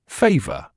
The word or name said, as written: favour